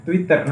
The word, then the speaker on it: Twitter: Eh, jang dulu, oh, perbaiki dulu. Twitter. Nah, ah, twi. Twitter. Asik. Twi. Twitter. Ter. Twitter. Bacanya Twitter.